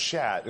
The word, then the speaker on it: sceat: it was called a shat. It's spelled S-C-E-A-T, but, you know, leave it to the English to pronounce something different than how it's spelled, right? So it was pronounced shat,